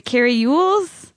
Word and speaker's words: Elwes: I've heard different things. I've heard Ewells and I've heard Elwes. – It's CaryElwes.